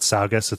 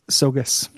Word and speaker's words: Saugus: It's not Saugus. It's Sogu. I've got fucking idea. – It's Sogus. – Sogus.